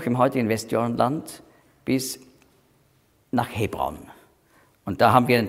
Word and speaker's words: Hebron: Hebron, hebräisch ausgesprochen Chevron, heißt Gemeinschaft.